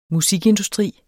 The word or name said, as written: musikindustri